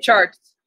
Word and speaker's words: Chartres: Speaking of Chartres.